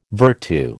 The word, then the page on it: vertu: American English